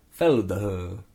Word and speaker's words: Feldherr: F-E-L-D-H-E-R-R. Felder. – Yeah, we'll go with Felder.